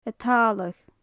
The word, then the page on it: a taladh: gaelic